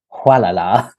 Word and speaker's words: Gualala: And it's in a place, it's spelt Gualala, but we think it's pronounced Hualala. Who knows there?